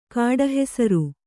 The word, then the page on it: ಕಾಡುಹೆಸರು: kāḍu hesaru; Kannada